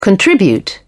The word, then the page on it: contribute: British English